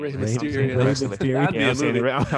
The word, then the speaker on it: Rey Mysterio: Rey Mysterio.